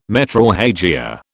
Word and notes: metrorrhagia: British English

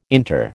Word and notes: inter: American English

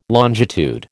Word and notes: longitude: American English

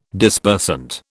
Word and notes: Dispersant: British English

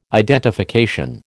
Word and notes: identification: American English

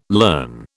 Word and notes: learn: British English

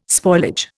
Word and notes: spoilage: Indian

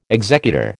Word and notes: executor: American English

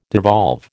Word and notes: devolve: American English

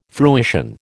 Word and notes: fruition: American English

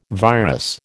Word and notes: virus: American English

